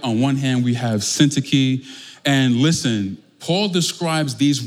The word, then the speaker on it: Syntyche: So I looked up various pronunciations for Syntyche, and I settled with the Brooklyn pronunciation. There was Syntyche, there was another one that was in the Greek, but tonight we're going with Syntyche, okay?